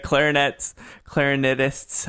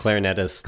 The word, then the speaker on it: clarinetists: The clarinetists, how do you say clarinists? – Clarinetists. – Clarinetists, that's very good.